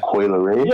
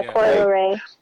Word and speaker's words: Coi Leray: Corey LeRae. I hope I said it right. Coi Leray. – Yeah, Coi Leray.